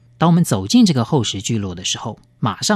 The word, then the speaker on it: 厚食: 厚实用客家话来发音，厚实。